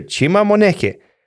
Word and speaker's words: Chima Moneke: There's every player pronouncing his name on the website. Mm. So just you click and you hear Chima Moneke.